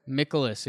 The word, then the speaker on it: Mikolas: Miles Mikolas, how you say it? – Mikolas, yeah. – Mikolas, 26.